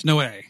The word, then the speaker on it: Noé: like if it's Noé or Noah or No. – I'm pretty sure it's Noé. – Oh, good, because that's how I've been saying it for years.